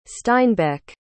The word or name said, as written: steinbeck